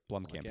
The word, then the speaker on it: Blomkamp: Blomkamp, yeah.